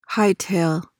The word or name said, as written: hightail